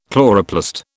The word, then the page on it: chloroplast: British English